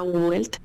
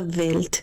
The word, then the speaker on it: Welt: Sí, sería en español sería Astrea Welt, pero correctamente se diría Astrea Welt. – Welt en alemán.